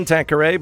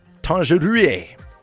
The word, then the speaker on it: Tangerie: You can pronounce it like the gin Tanqueray, but it's Tangerie, as it's spelt.